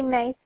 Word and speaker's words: Mae: Mackenzie May Smith. Mackenzie, M-A-C, capital K-E-N-Z-I-E. – Okay, and then May as in spelled like M-A-E. Yeah, okay. Like the month, but instead of a Y and E.